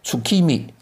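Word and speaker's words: 月見: 那么日本人呢也会赏月，但是日文呢叫做月见，就看见月亮，这个日文的读音叫 tsukimi 啊，tsukimi 月见。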